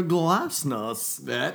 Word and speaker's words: glasnost: Can you pour a strika more drinks into this tumbler glasnost? – That's yeah. What you say. That's exactly how you say it. That's how Gorbachev said it. That's how Yeltsin said it.